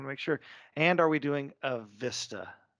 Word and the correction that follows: Vesta: Vesta. It's Vesta, not Vista. We said it wrong all these years.